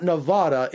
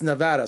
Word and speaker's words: Nevada: But you got to tell your co-hosts, you know, Tom Morris Jr. and Sean Stick Larkin, how to pronounce Nevada. It's not Nevada, it's Nevada.